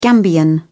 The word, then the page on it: gambian: British English